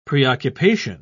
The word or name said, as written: preoccupation